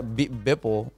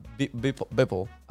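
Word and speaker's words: Beeple: There's this dude, I forgot, Bipple, or is that his name? – You said it right. – Bipple.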